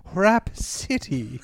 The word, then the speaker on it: Rapsittie: So it's called Rhapsody, but it's spelled interestingly. – Yeah, R-A-P-S-I-T-T-I-E. Rhapsody.